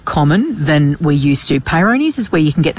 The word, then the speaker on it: Peyronie's: Is it Peyronie's and... – Peyronie's is another one.